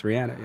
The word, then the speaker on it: Rihanna: Although, props to Harry for pronouncing Rihanna correctly, which I never do. I just say Rihanna. – Yeah. They're online. These writers, they know. – They do. – Rihanna. It's Rihanna. Yeah.